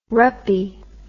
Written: ruppie

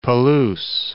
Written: Palouse

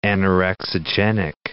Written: anorexigenic

